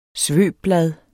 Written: svøbblad

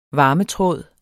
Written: varmetråd

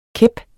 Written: kæp

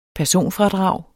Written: personfradrag

